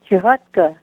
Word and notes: chihotka: Ninilchik Russian

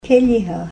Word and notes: keliha: Ninilchik Russian

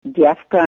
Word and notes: dyefka: Ninilchik Russian

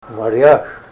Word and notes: maryash: Ninilchik Russian

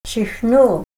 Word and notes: chihnul: Ninilchik Russian

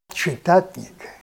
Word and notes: chitatn'ik: Ninilchik Russian

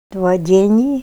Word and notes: dwa d'en'i: Ninilchik Russian

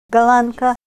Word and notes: galanka: Ninilchik Russian

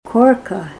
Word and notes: korka: Ninilchik Russian

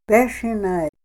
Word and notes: beshinay: Ninilchik Russian

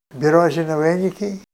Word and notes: birozina wen'ik'i: Ninilchik Russian

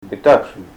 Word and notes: bitachi: Ninilchik Russian

